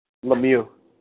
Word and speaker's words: Lemieux: Lemieux.